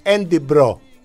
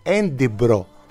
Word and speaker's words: Edinburgh: Φτάνεις εκεί και σου λένε Edinburgh. Καμία σχέση με τη γραφή.